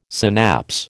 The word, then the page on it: synapse: American English